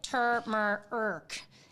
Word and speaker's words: turmeric: Oh, so I'm going to have to play this because it looks like turmeric, and there's a lot of people are Googling is the R silent in. Okay, hold on. Let's just play it. – Turmeric. – Well, we're all saying it wrong.